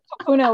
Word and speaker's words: kupuna: Kupuna, which is our ancestors.